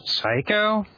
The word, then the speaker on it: Psycho: It also begins with P, in keeping with our last couple choices, and it also begins with a silent P, in keeping with your last choice. – Psycho? – Yep, it is Psycho.